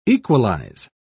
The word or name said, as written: equalize